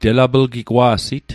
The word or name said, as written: telaplgigwa'sit